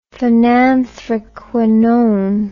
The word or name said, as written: phenanthraquinone